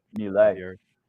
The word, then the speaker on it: Milei: Milei.